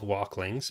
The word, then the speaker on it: Woklings: But she informed me that baby Ewoks are called Walklings, but it's spelled Woklings.